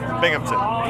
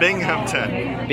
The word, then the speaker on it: Binghamton: Is that right? – Binghamton. – Binghamton. How do you say it? – Binghamton. – Binghamton. – Yeah. – Oh, I spelled it wrong. How do you spell it? – B-I-N-G. – Oh. – H-A-M. – Oh. Why didn't you tell me? – Sorry, man. – Binghamton. – Yeah, Binghamton.